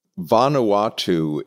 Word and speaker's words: Vanuatu: Is it Vanuatu? Is that how you pronounce it? – Vanuatu is the name of the country.